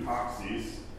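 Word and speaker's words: toxis (τάξις): Or they, the Greek word is a very Byzantine word too, toxis. You spell it taxis, but you don't pronounce it that way.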